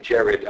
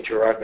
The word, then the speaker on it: Gerard: Like a letter to Hopkins saying, "Dear Jared," that's how, by the way, how you say his name, Jared. I know I'm going to say Gerard Manley Hopkins, but it's Jared.